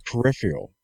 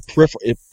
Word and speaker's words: peripheral: It lets you, because your device is a USB peripheral. Peripheral. I've said it wrong. That's a peripheral.